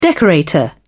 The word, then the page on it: decorator: British English